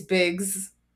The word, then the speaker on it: VIXX: And they were like, oh, I see why they call y'all VIXX, like Biggs, because the way VIXX is pronounced in Korean, it's Biggs.